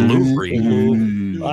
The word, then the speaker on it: Louvre: See, as a tourist, when I visited, say, the Louvre. Is that how you say it? – The Louvre.